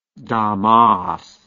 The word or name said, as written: Damas